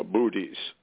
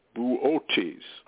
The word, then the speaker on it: Boötes: Some people call it uh, uh, booties, but the actual pronunciation, I'm told, is Boötes.